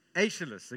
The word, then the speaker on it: Aeschylus: A Greek poet, 500 BC, a guy called Aeschylus, thank you, Linda. Linda's absolutely fluent in Greek. Aeschylus, a Greek poet.